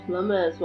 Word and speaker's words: plumber's: You just don't pronounce the B. – Oh yeah, the plumber's. – The plumber's, well done.